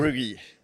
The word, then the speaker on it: Verbrugghe: Verbrugghe.